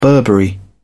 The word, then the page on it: burberry: British English